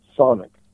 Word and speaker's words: Chthonic: I pronounce it Sonic. Just C-H-T-H-O-N-I-C.